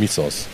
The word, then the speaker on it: Mythos: Das wird natürlich gar nicht Mythos ausgesprochen, sondern Misos.